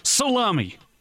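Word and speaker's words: Salemi: Apparently, there's an Italian town in Sicily called, and forgive my pronunciation here, I don't speak Italian, but it's S-A-L-E-M-I. Would that be salami? – Sure. – I believe it's pronounced salami.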